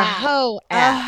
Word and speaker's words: Heaux: A whore app, yes, like a whore, but it's called Heaux app. It's spelled creatively. It's H-E-A-U-X, and it's a creative way to spell Heaux.